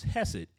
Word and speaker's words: chesed: so the English word is hesed, H E, or the English pronunciation is hesed, H E S D. All right, and for you all you scholars and everybody in here, my main idea to the passage is trusting in God's faithfulness.